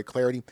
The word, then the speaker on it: Clardy: You can follow me on Twitter at Troy Clarity. Last name it's spelled C-L-A-R-D-Y at Troy Clarity.